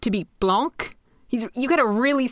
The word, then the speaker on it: Blanc: So, like Blanc. Yes. Well, except with the pronunciation still the same as blank.